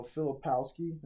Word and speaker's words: Filipowski: You know, Filipowski.